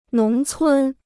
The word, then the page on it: 农村: nóng cūn